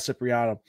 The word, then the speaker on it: Cipriano: And then letterer Sal Cipriano. Did I pronounce that correctly? – You did. You got it.